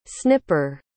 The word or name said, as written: snipper